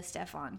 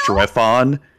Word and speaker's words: Strefan: And we've been saying the name wrong the entire time. It's Strefon. – Are you kidding? – So S-T-R-E-F-A-N.